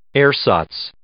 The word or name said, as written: ersatz